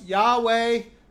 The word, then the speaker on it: Yahweh: which we pronounce Yahweh.